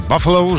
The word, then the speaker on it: Buffalo: Albo dobry wieczór, też tak może być, ale rozwiejmy te wątpliwości, no bo pisze się Buffalo, a czyta się Buffalo? – Tak, mamy teraz premierę jednego z najpopularniejszych anglosaskich komediopisarzy, więc Księżyc nad Buffalo. – No to w takim razie Księżyc nad Buffalo, żeby nie było żadnych wątpliwości.